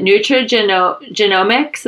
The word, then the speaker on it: nutrigenomics: She has expertise in nutrigenomics. Am I saying it right? – That's correct. Yeah.